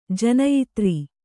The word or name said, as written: ಜನಯಿತ್ರಿ